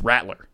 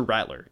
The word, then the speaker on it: Rattler: For the past, like, 3 weeks, I cannot say his name normally. I have to say Rattler, and I don't know why. I don't know why. It's Rattler. Spencer Rattler.